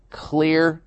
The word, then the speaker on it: Xlear: Clear nasal spray. And that's spelled X-L-E-A-R.